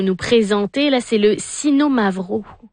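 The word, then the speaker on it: Xinomavro: On dit qu'il y a un accent sur le « no », donc mm-hmm. « Sino Mavro ». Donc, il y a vraiment une intonation importante.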